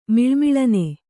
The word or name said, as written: ಮಿಳುಮಿಳನೆ